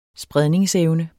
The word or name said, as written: SPREDE